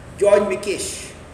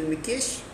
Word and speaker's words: Mikes: He's not an Englishman, he's a Hungarian, and he's called George Mikesh, right? Yeah, and it's spelled as Mikes, M-I-K-E-S, but this pronunciation is Mikesh, right?